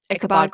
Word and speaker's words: Ichabod: So basically we have, it's Ichabod, right? That's how you say it? – Mm-hmm. Ichabod Crane.